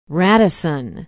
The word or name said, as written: radisson